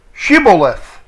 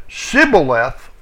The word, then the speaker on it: Shibboleth: If he said, "Nay," then they said to him, "Say now, Shibboleth." That's 7641. That means a flowing stream. And he said, "Sibboleth, 5451. That means a flood. For he could not fashion 3559 to say it right.